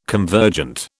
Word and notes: convergent: British English

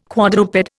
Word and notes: quadruped: Indian

